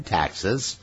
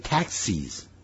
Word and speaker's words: taxes: And taxes are non-existent，税也是根本不存在的。 Existence 是存在的，non-existent 是不存在的。 Taxes 是 tax 的复数形，不要念成 taxis， taxis 把 e 的音呢变成计程车了啊、哦！